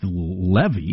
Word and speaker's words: Levi: It's L-E-V-I, and he's Jewish, but it's pronounced Levy.